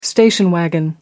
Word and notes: station wagon: American English

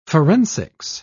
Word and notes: forensics: British English

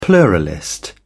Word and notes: pluralist: British English

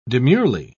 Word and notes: demurely: British English

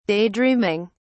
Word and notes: daydreaming: British English

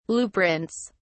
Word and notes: blueprints: British English

